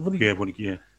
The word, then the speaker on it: Vunikefe: Is it Vunikefe? – Yeah. – And it's like every time he hear it on the right. But the thing is, that's the way it's pronounced. But that's actually how you pronounce it.